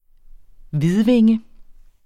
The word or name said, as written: hvidvinge